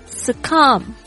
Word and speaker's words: Succumb: 接下来是 Yield Y-I-E-L-D Yield 是屈服、投降、放弃、退让的动词，还有 Succumb S-U-C-C-U-M-B B 不发音，是屈服的意思，还有死亡的意思。